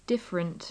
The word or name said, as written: different